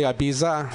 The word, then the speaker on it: Ibiza: So am I pronouncing your name correctly, Ibiza? – Ibiza. – Yeah, Ibiza. – What did you say, Pearl? – Ibiza. – Ibiza. – Like Ibiza. – The island in Spain.